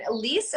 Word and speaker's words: Alysse: Elise, am I pronouncing that right? – You are, but that is my middle name.